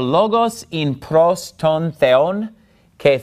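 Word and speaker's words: theon (θεόν): So notice, the word for God, theon, the accent is on the second syllable, that's where the mark is.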